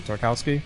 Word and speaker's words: Tartakovsky: So, and I love Genndy Tartakovsky. I forget how to say his last name. – Tartakovsky.